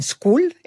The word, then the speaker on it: Skool: Esta comunidad la pueden encontrar en School, SKOOL, y la encuentran como Comunidad Autorpreneur.